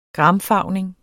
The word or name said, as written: gramfarvning